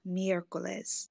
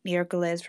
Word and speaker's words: miércoles: but in Spanish, Wednesday is called miércoles. So miércoles for Mercury. – Thank you. That's how you pronounce that.